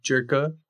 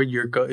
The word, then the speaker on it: Jirka: And his last name, Jerka or Yurka, if you want to pronounce it in such. J-I-R-K-A.